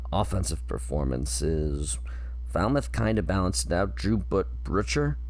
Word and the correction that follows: Brutcher: I want to say his name is Drew Butcher, but no, it's Brutcher, B-R-U-T-C-H-E-R, from South Florida.